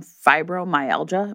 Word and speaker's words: fibromyalgia: And she, although she has, like, sort of made a life for herself, she suffers from fibromyalgia. Am I saying that right? – Yes, that's correct.